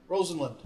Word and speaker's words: Rosenlund: Rosenlund. – Hey! – There you go. – Unfortunately, I can't even claim that because it's like... – I know. – Rhymes with Bozeland.